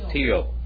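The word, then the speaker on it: tío: Tio. – T-I-O. Yeah, T-I-O, Tio.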